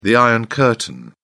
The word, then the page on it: iron curtain: British English